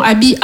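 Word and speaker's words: Aby: Non, comme je vous ai dit, Abby c'est mon prénom. Abby, A-B-Y, 3 lettres, 2 syllabes.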